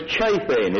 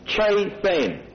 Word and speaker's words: chathan: And the word joined affinity in the Hebrew is chafen. It is spelled, for those of you who want to take it down, C-H-A-T-H-A-N. Chafen.